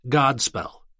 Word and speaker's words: godspell: The word gospel is derived from the Old English word godspell. God, good, plus spell, news, story. Contrary to popular opinion, the Old English word God has a long O and is a reference not to God, but to that which is good.